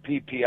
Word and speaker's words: Phi Phi: It is pronounced the Phi Phi Islands.